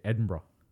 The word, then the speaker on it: Edinburgh: Like, most people would say Edinburgh, but again, it becomes, what should be a two-syllable, Edinburgh, becomes about four fucking syllables.